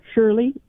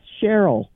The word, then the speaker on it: Cheryle: And I noticed that you pronounced my first name, Shirley. It's Cheryle.